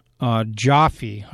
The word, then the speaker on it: Giuffre: There was a defamation lawsuit that was filed by Virginia Joffe. I think that's how her name is pronounced, Joff. It's spelled G-I-U-F-F-R-E.